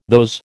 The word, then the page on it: those: American English